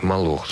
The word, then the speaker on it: Молох: Молох, с ударением на втором слове.